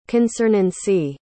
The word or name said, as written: concernancy